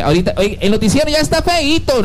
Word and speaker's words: feito: Yo diría que no eito. – Eito, no se dice eito. Hecho se dice así como, lo que es predicita. Feito. Feito.